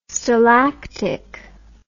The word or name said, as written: stalactic